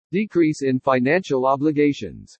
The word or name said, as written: DECREASE IN FINANCIAL OBLIGATIONS